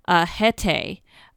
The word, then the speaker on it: Haetae: It's supposed to resonate, if you've ever looked up, and this is spelled H-A-E-T-A-E, Hete.